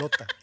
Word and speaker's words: Lotte: No, Lota. Sigue. Se escribe L-O-T-T-E. Cállate, pinche. L-O-T-T-E.